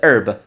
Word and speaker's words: herb: 我们草食性动物叫 herbivore，herbivore，因为那个字 herb，很多人会念 herb，其实是 h e r b，那 h 是不发音，所以这个叫 herbivore，herbivore，herb 就是花草的意思，最好记就是花草茶叫 herbal tea，herbal tea。